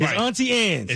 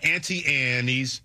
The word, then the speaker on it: Auntie Anne's: It's Auntie Anne's. – It's not Auntie Annie's, because Anne is an insect, Anne is a person, and it's Anne's. The E is silent.